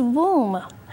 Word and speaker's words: womb: And another word for the uterus is womb. I like the word womb because it rhymes with the word room.